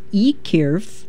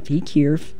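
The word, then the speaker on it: Y Cyrff: So it's Ekyrff, it's spelled Y space C-Y-R-F-F, Ekyrff. The song is Uog, E-U-O-G, and the band, I'm not even going to try to pronounce the LP name, you can look it up.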